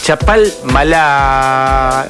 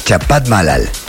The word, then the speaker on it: Chapalmalal: Primo Silvestre, desde Chapalmalal, siempre lo digo mal, por eso estuve un tanto dubitativo en cuanto a decirlo Chapalmadad, porque sabía que lo iba a decir mal, pero lo dije mal y acá estoy subsanándolo bien. Es Chapalmalal, ahora me entró la confusión, Chapalmalal, ¿no? De mal, ¿qué decís vos? – Es Chapalmalal.